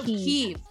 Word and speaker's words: Kyiv: No, Kyiv, because Kyiv, okay, was the Russian pronunciation. And once they separated from Russia, they changed it to Kyiv.